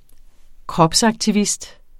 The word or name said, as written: kropsaktivist